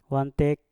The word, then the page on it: wantek: mpur